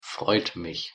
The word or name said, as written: freut mich